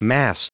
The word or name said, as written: mast